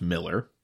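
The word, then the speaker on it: Miiller: Well, so the license plate reads, "It's, I believe it's pronounced Miller, but it's M-I-I-L-L-E-R, like Miller."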